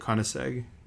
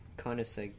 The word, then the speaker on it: Koenigsegg: Koenigsegg. – Koenigsegg.